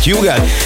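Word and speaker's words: Hugel: Így van, az úriembereknek minden országban másképp ejtik a nevét. Van, ahol Ugel, van, ahol Hügel, van, ahol Ugel, meg mindenféle kép, de nálunk úgy mondjuk, hogy Hügel, és Salto és a Jamaican Bam Bam érkezik, csavarjátok fel a hangerőt.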